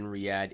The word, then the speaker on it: Riyadh: So maybe you can correct me on the pronunciation. So the capital of Saudi Arabia, is it called Riyadh? – Yeah, Riyadh.